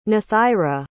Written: nathaira

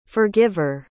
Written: forgiver

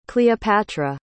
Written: cleopatra

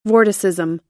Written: vorticism

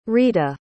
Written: rita

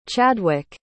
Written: Chadwick

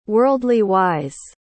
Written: worldly-wise

